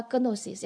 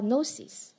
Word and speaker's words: Gnosis: 我不晓得大家怎样哈，这个知识就是还可以摁个红的哈，这个知识它的希腊文，哎，我会发音了， Gnosis， G 不发音哈，不要发 Gnosis，要发 Gnosis，你混勾就是 Gnosis，敢啊。然后呢，这个知识的意思就是说你个人经验所得的知识，就是你个人的经验，你自己去挖掘到的第一手知识。所以查到这个希腊文知识的意思是我的 Gnosis，